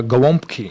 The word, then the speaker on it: gołąbki: Next dish is the cabbage rolls, pronounced gołąbki.